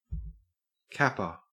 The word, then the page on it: capper: Australian English